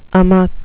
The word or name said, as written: amok